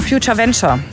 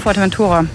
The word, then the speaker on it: Fuerteventura: Beispiel war zum Beispiel, er wollte einer nach Future Venture. Ja, er wollte nach Fuerteventura.